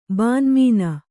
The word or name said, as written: ಬಾನ್ಮೀನ